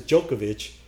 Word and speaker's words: Djokovic: Well, that's how you pronounce it, right? – Yeah, it was Djokovic.